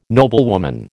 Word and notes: noblewoman: American English

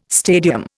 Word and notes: stadium: Indian

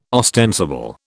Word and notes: ostensible: British English

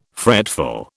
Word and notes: fretful: British English

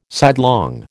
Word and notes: sidelong: American English

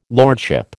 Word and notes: lordship: American English

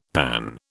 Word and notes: panne: British English

